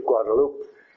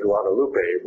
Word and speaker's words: Guadalupe: And she has been designated as Our Lady of Guadalupe. Of course, we in English say Guadalupe, but the Spanish people pronounce it Guadalupe, and that's the way she's been known ever since.